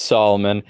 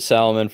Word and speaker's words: Salomon: It's Solomon. I pronounced it Salomon for a while. And then I was corrected. It's Solomon.